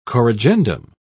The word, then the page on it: corrigendum: British English